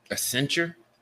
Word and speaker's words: Accenture: Accenture.